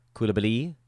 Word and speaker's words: Coulibaly: And you are you're kind of stashing Bilal Coulibaly, whose name is painfully difficult to say, and so we're going to continue to call him Cooley after saying it right one time. Coulibaly. Now he's Cooley the rest of the show.